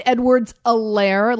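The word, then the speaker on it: Edwards-Helaire: So it's Clyde Edwards-Alaire, like Eclair Alaire. The H is silent.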